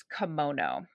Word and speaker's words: komono: which is kimono.